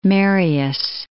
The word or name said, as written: Marius